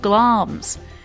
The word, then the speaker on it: Glamis: Hail to thee, Thane of Glam's!" And that word, Glam's, it's spelled G-L-A-M-I-S. – Glamis.